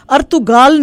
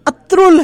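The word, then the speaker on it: Ertuğrul: जो हम अर्तुगाल नहीं है वो अत्रुल है, क्योंकि कुछ जो उसमें वर्ड्स हैं वो साइलेंट हैं।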